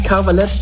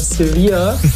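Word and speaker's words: Cervelat: Die Schweizer Wurst sehe ich nicht Cavalette, sondern Sevilla. Es klingt ein bisschen wie diese spanische Stadt im Süden Spaniens.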